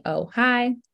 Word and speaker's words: Ojai: His name is Ojai, and um, spelled O J A I, phonetically Ohi.